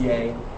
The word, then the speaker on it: Yae: Now we were approached by Yay Wellness, Y-A-E, it is pronounced Yay, about the county-owned property behind the Fork Union Fire Department and the Fluvanna Community Center.